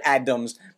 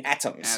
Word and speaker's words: atoms: That's actually what I heard, Adams, but you mean atoms.